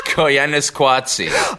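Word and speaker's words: Koyaanisqatsi: Say that again, please. – Koyanis Kwatsi. – Oh, God, have I been saying it wrong this whole time? – I told you that, Philip Glass.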